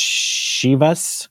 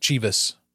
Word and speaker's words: Chivas: It's pronounced Shivas. – Shivas. Regal. Mm-hmm. Shivas Regal. Like S-H-I-V-U-H-S. Shivas. – Shivas. – Regal. – Not Chivas, but Shivas.